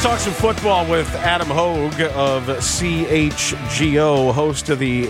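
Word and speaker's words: Hoge: Adam Hoagie or Hogue? Excuse me. I don't know how to pronounce the last name.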